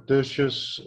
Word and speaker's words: Tertius: Yes, it's Tertius, and my surname is Fourie, yes.